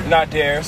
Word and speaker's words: Nadir: It's pronounced Nadir. – Nadir, sorry. I suck at names.